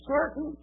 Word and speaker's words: Sartain: Hell for certain, Kentucky." Now, it's not spelled certain. It's spelled sartain, S-A-R-T-A-I-N.